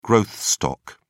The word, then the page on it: growth stock: British English